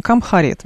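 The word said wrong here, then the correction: Cumhuriyet: видимо, так. – Джумхарет, да.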